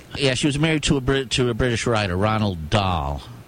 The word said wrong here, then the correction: Roald: R-O-A-L-D. – Roald Dahl. – D-A-H-L.